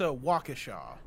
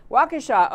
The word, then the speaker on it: Waukesha: And I first wanted that this, I'm talking about the guy in, and I'm not sure I ever pronounced the name of the town, but Washeka, whatever, Wisconsin. – I think it's Waukesha. – Waukesha. Okay, there's my happy. Okay, Waukesha, we'll take that. I'll take that answer.